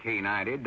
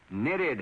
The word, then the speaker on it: knitted: Oh, no, Sally, that ain't canited, that's knitted headwear.